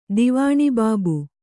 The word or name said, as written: ದಿವಾಣಿಬಾಬು